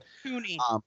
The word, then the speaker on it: Tunney: We have Christine, played by Robin Tunney or Turney. I don't know how to say it. – Tunney. – Tunney. There we go.